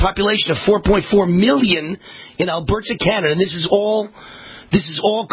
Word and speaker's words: Alberta: Okay, data from the Canadian province of Alberta, or I should say Alberta, Canada, stress the right syllable.